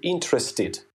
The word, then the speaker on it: interested: Действительно, когда наши слова прилагательные заканчиваются и не только прилагательные, глаголы, например, заканчиваются на звуке «т» или «д», действительно, при добавлении окончания «иди» мы произносим его как «ид». Например, interest – это интерес, заинтересованный будет interested.